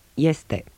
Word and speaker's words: este: At the beginning of a word, it is pronounced like ye, in yes. – Este.